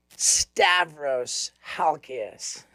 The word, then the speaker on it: Stavros Halkias: Ladies and gentlemen, Stavros Halkias.